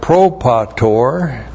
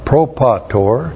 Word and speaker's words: propator: And then we have propator, P-R-O-P-A-T, long O-R, propator, which is forefather, genitive plural of the personal pronoun ego, our forefather.